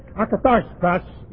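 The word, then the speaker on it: akathartos (ἀκάθαρτος): The Greek word akathartos is used, and I'm going to put the accent on the second syllable where they want it or not, is used 23 times in the New Testament as an adjective describing demons.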